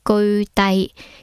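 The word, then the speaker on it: 俱胝: 呢个梵文。个读音系居低，咁啊，古时候印度里边数字系统里边无限啊，最大嘅数，咁亦叫千万啦。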